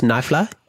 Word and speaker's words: Niffler: It's actually pronounced Niffler.